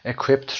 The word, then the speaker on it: equipped: نکته هم برای تلفظ عرض کنم خدمتتون که در کتاب قبلی انجام دادیم، equipped رو اینجا ما ته تلفظ میکنیم، equipped to make visitors نمیگیم equipped. تلاش نکنید که این کارو انجام بدید، د و ت چون نزدیک به هم هستن صدای ت رو ما برای قسمت ت ادا میکنیم، چون در این قسمت ما پ داریم که پ بی صدا هست، ما در رو اینجا ت تلفظ میکنیم، از طرفی هم در اینجا تو داریم که خیلی نزدیک به هم میشن، equip to make visitors feel welcome.